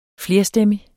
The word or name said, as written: flerstemmig